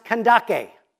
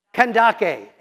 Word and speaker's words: Kandake: It was located in what's now Sudan, and it was a title for the queen mother or the female ruler, and it should be pronounced Kandake. So say that with me, Kandake, and it's the Kandake.